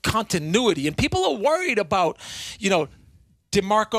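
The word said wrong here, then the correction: DeMeco Ryans: D'Amico Ryans.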